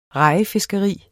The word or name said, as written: rejefiskeri